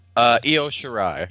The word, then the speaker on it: Io Shirai: how do you pronounce it? – Io Shirai.